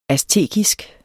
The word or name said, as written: aztekisk